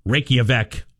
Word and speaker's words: Reykjavik: You know, a place like Reykjavik, Iceland.